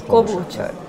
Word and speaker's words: Klobuchar: Klobuchar.